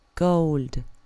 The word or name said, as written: gold